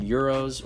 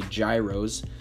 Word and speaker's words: gyros: They have the best cheeses, from feta to manouri, to the best meats you can ever ask for, from gyros, or as Americans say, gyros, to paideika, which is almost like a pork chop.